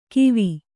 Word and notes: ಕಿವಿ: kivi

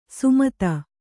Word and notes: ಸುಮತ: sumata

